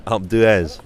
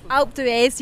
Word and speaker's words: Alpe d'Huez: But, you know, for example, the Alpe d'Huez. Have I said that right? – Alpe d'Huez. – Alpe d'Huez, yeah, that's it.